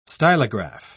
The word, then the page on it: stylograph: British English